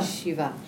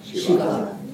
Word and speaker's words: Shiva: Shiva. – Shiva. – Nice. Is that a long I? No, not here.